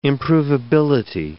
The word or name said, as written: improvability